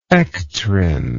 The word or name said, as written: ectrin